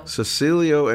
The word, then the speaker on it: Cecilio: But back in the 70s, they were Cecil, am I saying it right? – Cecilio and Capono, and they were like the England Dan and John Fort Coley of Hawaii.